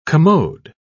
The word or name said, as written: commode